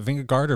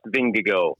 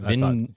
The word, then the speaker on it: Vingegaard: I mean, that's, but is it Vingegaard or Vingego? I think it looks like Vingegaard. – It's but it's pronounced Vingego. – That's what Vingego. – Vingego. – Vingego. – Vingego, according to Johan Vermeil.